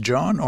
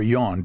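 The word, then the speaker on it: Jon: John Gordon, and I'm really uncomfortable because I've never met this man, and it's J-O-N, and that can be pronounced John or Yon, depending on your cultural background and your what you prefer.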